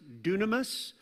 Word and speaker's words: dunamis: It's pronounced dunamis. Sounds like dynamite.